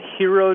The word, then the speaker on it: Jiro: It's called Hero Dreams of Sushi. Have you heard this? – No. – But it's spelled J-I-R-O.